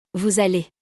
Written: vous allez

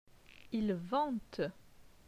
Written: Il vente

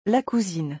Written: La cousine

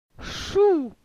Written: Chou

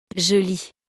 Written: je lis

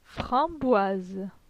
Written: Framboise

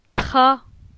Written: Tra

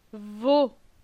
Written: Vo

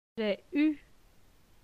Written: J'ai eu

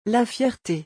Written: la fierté

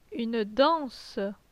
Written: Une danse

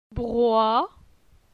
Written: Broi

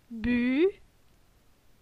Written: Bu

